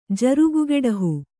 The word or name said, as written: ಜರುಗುಗೆಡಹು